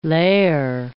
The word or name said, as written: lair